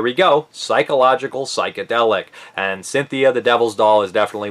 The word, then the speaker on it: Sinthia: Her name is actually Cynthia with a C, but they spell it S-I-N-T-H-I-A.